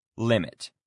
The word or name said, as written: limit